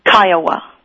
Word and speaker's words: Kiowa: Is it the Kiowa tribe? – Kiowa.